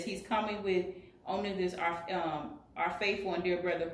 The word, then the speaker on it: Onesimus: Onesimus is coming with Tychius.